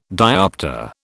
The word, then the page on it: dioptre: British English